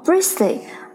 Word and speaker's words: bristly: 虽然呢，这个拼写中呢有一个 t，但是这个 t 呢是不发音的，读作 bristly，而不是 bristly。